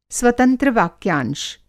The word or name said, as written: स्वतंत्र वाक्यांश